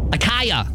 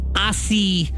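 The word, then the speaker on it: açaí: Akaya. – See, I would have said that too when Kat tells me to go get that drink or whatever. But it's acai. Is how you say that one.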